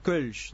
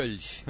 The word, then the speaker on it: Kölsch: Kölsch, o mejor dicho Kölsch, debería pronunciar. – Kölsch.